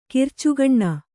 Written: ಕಿರ್ಚುಗಣ್ಣ

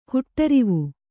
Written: ಹುಟ್ಟರಿವು

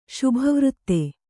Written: ಶುಭವೃತ್ತ

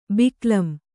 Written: ಬಿಕ್ಕಲಂ

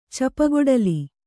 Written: ಚಪ್ಪಗೊಡಲಿ

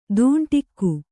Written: ತುಂಡಿಕ್ಕು

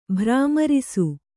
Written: ಭ್ರಾಮರಿಸು

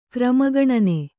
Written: ಕ್ರಮಗಣನೆ

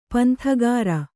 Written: ಪಂಥಗಾಱ

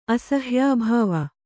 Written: ಅಸಹ್ಯಾಭಾವ

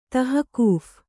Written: ತಹಕೂಫ್

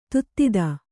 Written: ದದಾತಿ

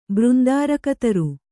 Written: ಬೃಂದಾರಕತರು

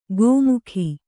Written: ಗೋಮುಖಿ

